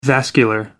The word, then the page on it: vascular: American English